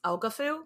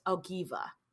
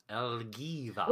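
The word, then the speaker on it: Ælfgifu: Last time we pronounced this Elgafu, because that's how it looks when it's written. But I listened to and watched a YouTube video. I listened to a podcast about her and watched a YouTube video about her because I went down a rabbit hole. And it's pronounced Elgiva.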